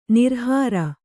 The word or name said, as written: ನಿರಾಹಾರ